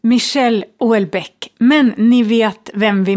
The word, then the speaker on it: Michel Houellebecq: Mikael Hollebeck, uttalar man hans namn så? – Du, det är Michel Houellebecq har jag hört.